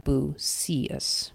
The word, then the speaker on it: Bucius: And I think we're supposed to pronounce that Bucius with the emphasis on the middle syllable.